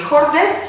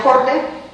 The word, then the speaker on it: Haarde: Y son el anterior primer ministro, Geir Horde, Haarde se escribe, pues se pronuncia Horde, y luego 3 ministros, asuntos exteriores, a ver si me acuerdo bien, finanzas y economía y comercio.